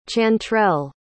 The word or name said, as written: chantrell